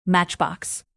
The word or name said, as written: matchbox